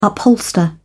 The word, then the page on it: upholster: British English